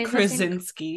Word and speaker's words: Krasinski: So I think I would do the Stanley Tucci, John, how do you say Krasinski? – Krasinski, and the Blunt sisters.